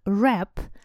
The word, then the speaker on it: wrap: У нас с вами есть глагольчик wrap, wrap, причем пишется он с буковкой W, впереди W-R-A-P, W-R-A-P. Но буковка W не, при, не произносится, поэтому wrap – Противоположность будет unwrap, разворачивать.